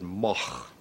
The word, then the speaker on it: Moog: Well, there are three ways of pronouncing M-O-O-G. It's originally a Dutch name. This goes back, you know, 1,000 years or so. And in Holland, you would pronounce it moch. Now, that's a little bit too much to expect people to do here. In Germany, it's a fairly common name, and there it's pronounced moog. In Great Britain, English-speaking countries, people see the double O and they say moog. Now, I just happen to like the sound of moog better than moog. Part of my family pronounces it one way, and part pronounces it the other way.